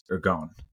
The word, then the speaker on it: ergon: ergon am i pronouncing that yeah.